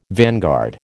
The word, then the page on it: vanguard: American English